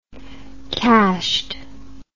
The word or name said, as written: cashed